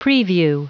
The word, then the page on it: preview: anglais